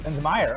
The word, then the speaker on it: Linsmayer: Is it Linsmayer? – Linsmayer, yep. – Linsmayer.